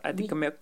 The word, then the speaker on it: Atikamekw: Ben, Nikotwaso, c'est dans ma langue Atikamek. Moi, je suis Atikamek et... Même là, je vais le prononcer comme il se doit, Adikomek.